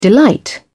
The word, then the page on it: delight: British English